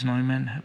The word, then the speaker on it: Neumann: One is John Newman, or Neumann, if you pronounce the name in German, is Neumann, but he was someone who was born in present Czech Republic, Moravia area, and then he came to the United States.